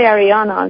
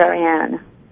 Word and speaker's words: Ariane: Sometimes I say Ariana because people sometimes have a hard time pronouncing it. So it's right. – Ariana, but it's Ariane.